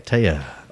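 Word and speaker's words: Téa: Taya Leone, is that how you pronounce it? Taya. – Taya.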